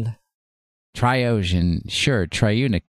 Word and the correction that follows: Reuniclus: Reuniclus, I believe is how they say it.